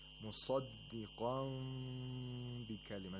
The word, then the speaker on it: مصدقا بكلمة: As you can see, the lips are slightly open when pronouncing this word. مصدقا بكلمة. You don't say مصدقا بكلمة. There should be a small space in between the lips.